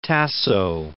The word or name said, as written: Tasso